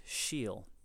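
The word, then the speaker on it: síol: And one of those words is shiel. Shiel is pronounced, or it's spelled S-I-O-L.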